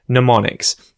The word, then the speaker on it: mnemonics: I think it's mnemonics, right? It's got a silent M at the front, mnemonics.